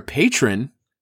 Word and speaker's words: patron: But if you're a Patreon, or if you're dang it, I keep saying it wrong. If you're a patron.